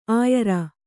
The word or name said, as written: ಆಯಾರ